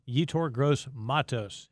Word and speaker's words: Yetur Gross-Matos: One of the more commonly mispronounced names of the draft, people are knowing it now, Yetur Gross-Matos.